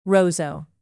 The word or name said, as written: roseau